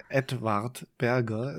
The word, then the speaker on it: Edward Berger: Edward Berger. Ist, ich darf es nicht englisch aussprechen. Ist ein Deutscher.